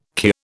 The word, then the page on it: kir: British English